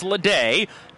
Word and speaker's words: Lada: A fastball for a called strike of the knees to shortstop Bobby Lede, spelled L-A-D-A, but pronounced Lede.